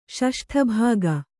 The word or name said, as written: ಷಷ್ಠಭಾಗ